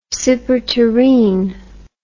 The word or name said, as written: superterrene